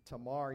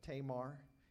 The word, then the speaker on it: Tamar: Tamar, you might pronounce it, but Tamar is how I've always heard it.